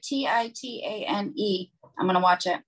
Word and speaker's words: Titane: Have you seen the French movie Titane? It's spelled like Titan, but with an E at the end, Titane.